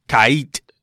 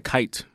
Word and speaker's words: Kait: Kait is how you pronounce her name. – K-A-I-I-T. – Facts. Yeah, exactly. That alone should make, like, it's pronounced differently. Because you out of say Kate. – Yeah, I said Kite. – Oh, Kite. – When I first saw it, too. It could be like Kite.